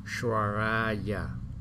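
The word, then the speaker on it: Valokiteśvarāya: Đó, cái chữ S mà có dấu gạch, dấu sắc trên đầu á, làm đọc là sh sh sh, cong lưỡi vào sh. Shvaraya, Bodhisattvaya, Maha Sattvaya, Maha Karunikaya, Om Sarva Rabaye, Sudanadasya, Namo Skitwa Imong Arya.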